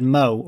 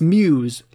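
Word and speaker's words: Meaux: It's M-E-A-U-X, like a French word. – Wow. – So I would have said Mo or something like that, like Bordeaux. – Yeah. – But it's Muse, like Bordeaux.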